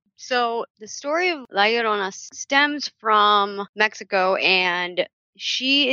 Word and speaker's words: La Llorona: La Llorona? – That's right.